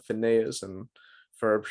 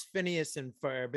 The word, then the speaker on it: Phineas and Ferb: That would be the person who would go on to create the Phineas and Ferb show. I don't even know if I said that right. – No, it's Phineas and Ferb.